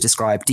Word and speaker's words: Dscryb: Head over to Describe, D-S-C-R-Y-B. That's how it's spelled.